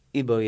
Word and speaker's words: Igbo: Do you pronounce it Igbo? – Igbo, yeah.